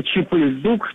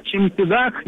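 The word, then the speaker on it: cempedak: Да, это такой фрукт, они его произносят как Чимпидак, но я проверял, что если подойти и попросить Чипыльдук, то они выдадут все равно Чимпидак.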